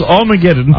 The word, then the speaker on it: Armageddon: I'm kidding, it's Armageddon. I know how to pronounce that.